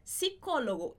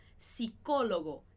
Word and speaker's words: psicólogo: Psicólogo, esse PS em espanhol, esse P não é pronunciado. Então, a gente fala psicólogo, psicólogo, como se não tivesse o P.